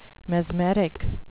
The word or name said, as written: mesmeric